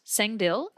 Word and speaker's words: Sangdil: But the film is, I believe, pronounced Sangdil, and that's what we watched and what we're about to talk about.